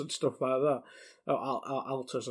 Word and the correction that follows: Atlus: I should say. Can't even pronounce the creators right. But is this a huge announcement, or am I overplaying it there? – Firstly, it is actually Atlus.